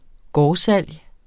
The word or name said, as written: gårdsalg